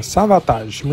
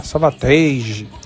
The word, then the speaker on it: Savatage: O Savatage, é assim que pronuncia, Savatage. Muita gente fala Savatage, mas na verdade esse nome vem de Savage Avatar.